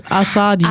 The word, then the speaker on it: Azadi: hier steht sogar Xai und Azadi. – Ah, oh. – Betonung der zweiten Silbe.